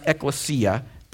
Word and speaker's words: ekklesia: When the word church is used in the New Testament, it's a Greek word that's pronounced ekklesia.